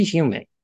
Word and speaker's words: Heumann: I may be mispronouncing her last name. Judy Holzman or Holzman? – Judy Heumann. – Heumann, okay.